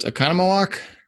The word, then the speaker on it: Oconomowoc: And was it Oconomowoc? – Yes.